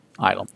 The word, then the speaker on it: Ylum: The moon Ilum, and it's spelled Y-L-U-M, Ilum.